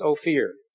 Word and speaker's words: Ophir: The gold of Ophir, or some pronounce Ophir,